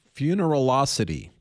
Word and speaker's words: Funeralocity: Fu- funeral Funeralocity. Did I say that correctly? Yes, that's right. Funeral with an osity at the end.